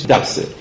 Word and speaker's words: دبس: من تلفظش رو اینجا میگم که مولانا این کلمه رو دبس رو با نحس و با نفس قافیه کرده، بنابراین تلفظش دبسه.